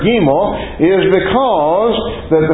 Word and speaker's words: gimel: Now I know how it's spelled, but sources I have say that's spelled like a long E, gimel.